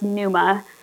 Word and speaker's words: pneuma: So it's a word that's pronounced pneuma, but you can use it for either word, either meaning of the word.